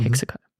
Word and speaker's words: HEXACO: Hexaco.